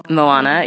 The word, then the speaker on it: Moana: Moana, yeah.